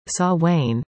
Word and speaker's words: Samhain: The first suggests it came from the Celts, who left food out to appease spirits traveling the earth during Samhain, pronounced Samhain, because everything spooky wants to have a kooky spelling that looks more cool, like magic and foolish.